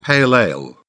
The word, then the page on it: pale ale: British English